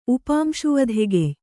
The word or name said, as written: ಉಪಾಂಶುವಧೆಗೆಯ್